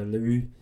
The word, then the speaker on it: Leroux: But it's by Gaston Leroux. Is that how you pronounce that? – It's French, right? – There's an X at the end, and it's French, so Leroux.